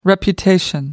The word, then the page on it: reputation: American English